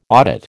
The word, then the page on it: audit: American English